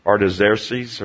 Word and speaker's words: Artaxerxes: Artaxerxes or something like that. The X's are pronounced with a Z's, but I won't tongue-tie myself.